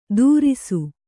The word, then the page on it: ತುರಿಸು: kannada